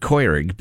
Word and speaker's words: Keurig: Or as my little brother pronounces it, Keurig, because that's the proper German pronunciation, and he's that sort of person.